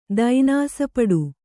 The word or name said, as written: ದೈನಾಸಪಡು